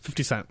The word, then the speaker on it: 50 Cent: The rapper 50 Cent. Okay, did I say that right? Sesame broccolini. – Fact check true. 50 Cent.